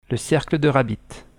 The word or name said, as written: khabit